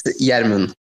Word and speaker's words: Yermin: It's Yermin. – Yermin. I've been calling you German all this time. Yermin.